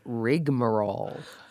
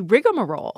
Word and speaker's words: rigmarole: And the one that I'm thinking of is one that I didn't know I was saying wrong, and it's rigmarole. – I really wanted to be rigmarole. – Yes. – So this one I looked into rigmarole, and I'm going to have to say that slowly so I don't add that extra syllable, goes back to 1736, and it seems to come from the phrase ragman's role.